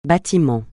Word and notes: bâtiment: francais

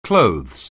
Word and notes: clothes: anglais

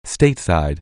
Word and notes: stateside: anglais

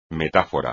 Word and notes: metáfora: Spanish

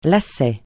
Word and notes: lacet: francais

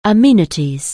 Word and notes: amenities: anglais

